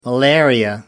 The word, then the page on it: malaria: American English